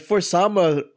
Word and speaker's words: For Sama: But for Sama rhymes with Obama.